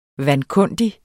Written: vankundig

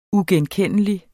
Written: uigenkendelig

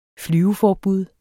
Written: flyveforbud

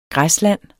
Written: græsland

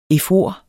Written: efor

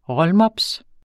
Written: rollmops

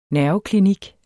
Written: nerveklinik

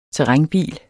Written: terrænbil

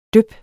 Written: dyp

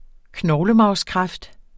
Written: knoglemarvskræft

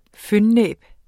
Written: fønnæb

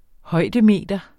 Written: højdemeter

